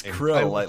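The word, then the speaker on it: Chrill: No, it's Krill, like the shrimp.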